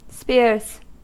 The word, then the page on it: spears: American English